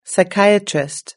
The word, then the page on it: psychiatrist: British English